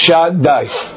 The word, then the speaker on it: Shaddai: Shin, Dalet, and Yud, which is pronounced Shad, Dai.